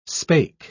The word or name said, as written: spake